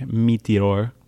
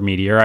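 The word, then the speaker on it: Météore: It's like, it's pronounced like Meteor or something like that. – Oh, I thought you meant like Meteor Shower. – It kind of is, but it's like M-E-T-E-O-R-E.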